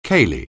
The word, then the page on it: ceilidh: anglais